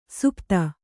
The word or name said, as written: ಸುಪಥ